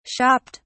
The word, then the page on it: shopped: British English